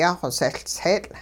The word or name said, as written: yaaxosehLtseetL'